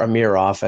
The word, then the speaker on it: Amirov: Well, I think Leaf fans who were critiquing the first-round pick of Rodion Amirov, or Amirov, I've been told that's the proper pronunciation,